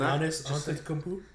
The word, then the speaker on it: Giannis Antetokounmpo: And Giannis Antetokounmpo? – Giannis.